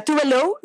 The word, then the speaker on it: Tove Lo: Se llama Tove Lo, o sea, Tove Lo es la pronunciación, pero su nombre real es Eva Tove Elsa Nilsson.